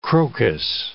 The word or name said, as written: crocus